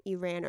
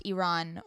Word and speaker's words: Iran: Like, it hit me that when someone experiences a tragedy, let's say in Iran or Iraq, pronounced Iran or Iraq. – Oh, okay. Sorry.